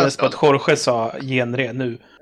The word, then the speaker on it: genre: Vem uttalar genre som det stavas. Och dels... – genre heter det, för helvete.